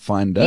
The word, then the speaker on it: Findar: Okay, so the app's name is Finder. We pronounce it Finder. – Yes. – But it is actually spelled Findar.